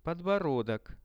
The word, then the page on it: podborodok: Russian